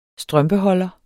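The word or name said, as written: strømpeholder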